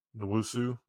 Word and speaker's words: Nwosu: I'm not even going to try and pronounce Nosu. Nwosu. There we go.